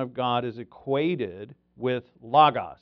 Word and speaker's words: logos: The Word in Greek is logos, L-O-G-O-S, which, you know, in the beginning was the Word in John 1:1. In the beginning was the logos, or logos, it's pronounced.